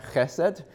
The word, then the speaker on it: chesed: All right, so now you're learning one more Hebrew word, hesed. All right. It actually is pronounced with a little throat sound, chesed.